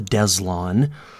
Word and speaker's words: Deslondes: They're called the Deslon. Yeah, that's how you say it. D-E-S-L-O-N-D-E-S, the Deslon.